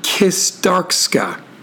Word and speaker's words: Kistarcsa: I'm going to say it phonetically. It's Kistarska. K-I-S-T-A-R-C-S-A.